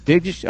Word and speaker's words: Begich: is it pronounced Bajish? – Or Bigish. – Bigish, all right, B-E-G-I-C-H, folks.